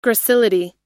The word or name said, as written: gracility